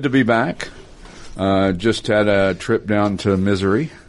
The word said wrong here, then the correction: Missouri: Sorry. I had the emphasis on the wrong syllable there. Down in Missouri, where my son got married.